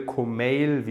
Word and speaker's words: Komeil: Kumail, wie, wie gesagt, Mail bei E-Mail, wäre die richtige äh, Aussprache.